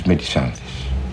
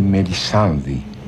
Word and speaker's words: Μελισάνδη: Πρέπει καταρχήν να σας εξηγήσω ότι εννοώ τη Μελισάνθη όπως εδώ προφέρεται, αλλά κανονικά είναι η Μελισάνδη, διότι αφορά τον Πελέα και Μελισάνδη του Μέτερλινγκ,